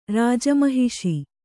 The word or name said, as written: ರಾಜಮಹಿಷಿ